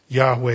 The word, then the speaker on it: Yahweh: Yahweh is how we pronounce it. That, if that's not perfect, it's close.